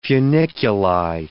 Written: funiculi

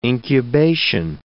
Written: incubation

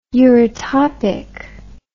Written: eurytopicity

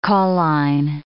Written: cauline

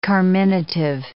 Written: carminative

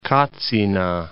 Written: Katsina

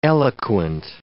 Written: eloquent

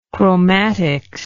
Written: chromatist

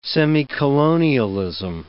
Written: semicolonialism

